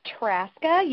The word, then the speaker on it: Traska: Oh, it's Traska. You don't say the Z.